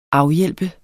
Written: AFHJALP

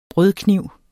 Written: brødkniv

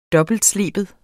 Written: dobbeltsleben

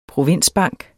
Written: provinsbank

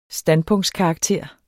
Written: standpunktskarakter

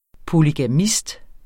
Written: polygamist

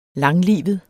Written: langlivet

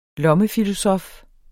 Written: lommefilosof

